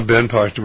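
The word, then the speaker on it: Ben Partin: Well, and then there was a general, Ben Parton. I believe I said that correctly. – Yeah, Ben Parton, right.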